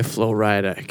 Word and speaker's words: Flo Rida: My House by Flo Rida. Gosh, Rick is just on top of it right now. Oh, I love it. – It's pronounced Florida.